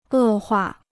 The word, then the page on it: 恶化: è huà